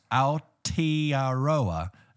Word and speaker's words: Aotearoa: Hey boys, Mads here from Aotearoa. – No, whoa. – Pronounced Aotearoa.